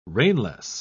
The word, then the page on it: rainless: British English